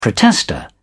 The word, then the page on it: protester: British English